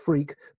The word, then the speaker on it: Freke: I have a very strange name, which is an old English name, pronounced Freke, but written F-R-E-K-E.